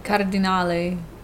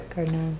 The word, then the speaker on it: Cardinale: And it was Alex Cardinale, I think is how it's pronounced. – Yeah, it'd be Cardinale in Italian, but I think they say it the American way. Yes, it's Cardinale.